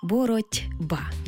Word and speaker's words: боротьба: Але вимовляємо і чуємо це слово зовсім інакше. Боротьба, бо дзвінкий приголосний «б» вплинув на попередній глухий «т» і зробив його дзвінким «д».